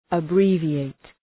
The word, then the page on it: abbreviate: ə’bri:vı,eıt